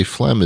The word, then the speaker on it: phlegm: And by the way, phlegm is spelled like all wrong. Phlegm should be F-L-E-M. Let's face it. You know it and I know it. Bob Dole knows it. It should be F-L-E-M-M.